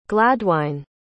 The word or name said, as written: glaedwine